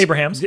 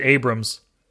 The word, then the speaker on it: Abrahams: But Jim Abrams, or is it Abrahams? – Yeah, Abrahams. – Abrahams. – Or I think he did pronounce it Abrams.